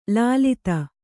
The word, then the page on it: ಲುಲಿತ: lulita